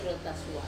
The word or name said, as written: rota sua